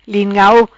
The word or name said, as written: Lin Ngau